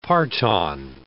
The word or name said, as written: parton